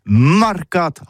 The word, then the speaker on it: marcat: Eh bien, d'ici à toutis, le mercat de l'Uni ou le mercat des rebelles, mais en gascogne toulousano, c'est prononcé sur les marcat, avec un A, marcat.